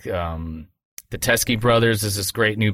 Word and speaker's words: Teskey: Yeah, Teskey, T-E-S-K-E-Y Brothers.